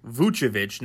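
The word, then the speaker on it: Vučević: Vucevic, and that is how you pronounce his last name, Vucevic, not Vucevic, it is Vucevic, is a bona fide All-Star.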